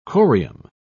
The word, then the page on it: corium: British English